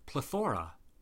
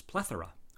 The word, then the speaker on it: plethora: Then you have a plethora, right? Wrong. The proper way to pronounce the word is plethora, with a stress on the first syllable.